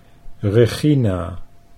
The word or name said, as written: Regina